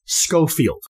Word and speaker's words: Schofield: Anyway, for this episode, I will pronounce it as Schofield, like the S-C-H in school.